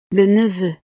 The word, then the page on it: le neveu: frances